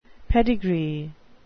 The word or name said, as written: pedigree